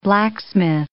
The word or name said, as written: blacksmith